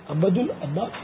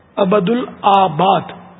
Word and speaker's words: عبد العباد: عبد العباس یا عبد العباد؟ آباد, عبد اس میں آباد لکھا ہوا تھا میں پڑھ گیا, عبد العباد صحیح تلفظ ہے.